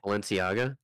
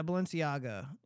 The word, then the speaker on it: Balenciaga: These are Balenciaga boots. I think I'm saying that correctly. – Balenciaga. – Yeah, Balenciaga. Yeah,